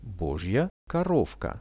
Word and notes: bozhija korovka: Russian

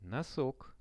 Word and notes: nosok: Russian

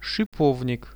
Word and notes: shipovnik: Russian

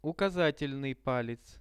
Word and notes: ukazat palec: Russian

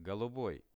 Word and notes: goluboi: Russian

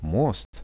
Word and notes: most: Russian

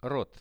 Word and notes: rot: Russian

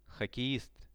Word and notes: hockeist: Russian

